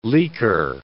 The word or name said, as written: leaker